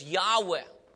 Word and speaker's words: Yahweh: It is the name of God, and just four consonants, and we pronounce it as Yahweh.